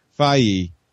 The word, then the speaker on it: Feige: And, you know, Kevin, is it Feige? I always say his name Feige